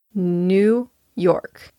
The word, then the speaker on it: New York: New York. Be sure to stress York and not new. I've had a lot of students say New York, but it's New York.